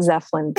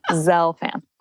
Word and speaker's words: Zelfand: You don't have to remember three different URLs, but so if you only remember one, Dr. Zelfand. And I'll say my name is not Zeflin. – Zelfand. – Z, elf, like a Christmas elf, and like, yes, and Zelfand.